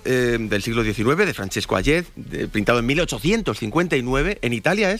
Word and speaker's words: Hayez: Hayez.